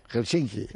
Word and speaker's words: Helsinki: Helsinki. – Así, no, en finés siempre hay un acento en la primera sílaba.